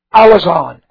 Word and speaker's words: Alazon: Alazon. A-L-A-Z, long O-N.